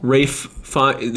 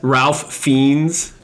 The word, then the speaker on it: Ralph Fiennes: Rafe Fiennes is how you actually say his name. – Dude, that's... – Ralph Fiennes. – Dude, hold on. – No, his name is Rafe Fiennes. – R-A-L-P.